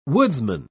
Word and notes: woodsman: British English

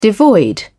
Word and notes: devoid: British English

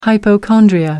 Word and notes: hypochondria: American English

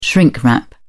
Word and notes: shrink-wrap: British English

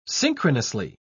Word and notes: synchronously: British English

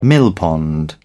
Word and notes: millpond: British English

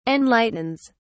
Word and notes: enlightens: British English

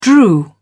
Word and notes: drew: British English

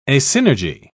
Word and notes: asynergy: British English

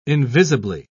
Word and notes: invisibly: British English

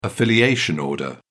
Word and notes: affiliation order: British English

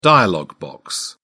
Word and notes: dialog box: British English

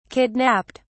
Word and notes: kidnapped: British English

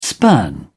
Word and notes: spurn: British English

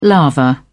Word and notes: lava: British English

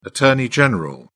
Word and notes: attorney general: British English

